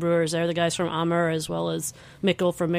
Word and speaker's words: Amager: Amager, that's how you say it. A-M-A-G-E-R.